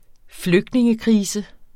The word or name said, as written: flygtningekrise